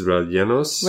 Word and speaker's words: rellenos: I believe it's pronounced tostone rellenos. – Llenos. – It's R-E-L-L-E-N-O-S. How would you say that? Tostones rellenos? – Rellenos.